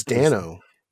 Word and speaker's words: Dano: I really like when Paul, is it Dano or Dano? I don't know how he pronounces. – I believe it's Dano.